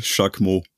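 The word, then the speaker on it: Jaquemon: Ganz simpel, Jacques Mo.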